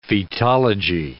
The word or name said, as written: fetology